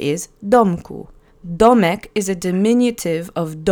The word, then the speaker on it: domku: Accent is on the first syllable. Domku.